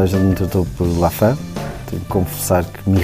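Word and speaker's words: Laffan: O que pouca gente sabe é que Lafan não é a pronúncia correta. – Lafan vem da minha mãe, que é inglesa. Uh, toda a gente acha que é francês, mas não, é, é inglês.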